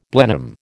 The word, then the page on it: Blenheim: American English